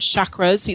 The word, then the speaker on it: chakras: And you might have heard chakras. It's spelled C-H-A-K-R-A-S, but it's pronounced at least here in the Western world as chakras.